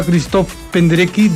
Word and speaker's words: Krzysztof Penderecki: Ella fue ganadora de la beca Erasmus, gracias a la cual estudió en la Academia de Música Krzysztof Penderecki de Cracovia.